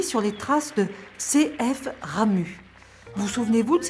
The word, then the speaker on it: Ramuz: Ramu. Ça s'écrit R-A-M-U-Z, mais on ne prononce pas le Z.